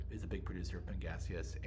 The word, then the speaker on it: Pangasius: And then there's another fish that I can't pronounce, Pangasius. – Pangasius, you did it, you nailed it.